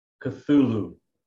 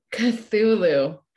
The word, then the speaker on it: Cthulhu: Cthulhu. – Cthulhu, Amalgamated Cycle.